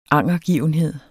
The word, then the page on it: angergivenhed: ˈɑŋˀʌˌgiˀvənˌheðˀ; -ˌgiːvən-